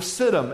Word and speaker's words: Siddim: Valley number one, write this down, is the Valley of Siddim, S-I-D-D-I-M. Sometimes it is spelled S-I-T-T-I-M. But for today, S-I-D-D-I-M, the Valley of Siddim, this is the Valley of the Slime Pits, the Valley of the Tar Pits.